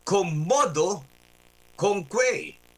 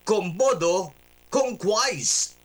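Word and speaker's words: quomodocunquize: Ang spelling niya ay Q-U-O-M-O-D-O-C-U-N-Q-U-I-Z sa iba at E. Q-U-O-M-O-D-O-C-U-N-Q-U-I-Z-E. This is a Latin verb. Okay? Latin verb po ito na ang Latin pronunciation ay commodo conque. Ba, natuto si Johnny G ngayon. Alright? Ang Latin term po nito ay commodo o Latin pronunciation ay commodo conque. Pero ang English pronunciation po nito ay commodo conquise.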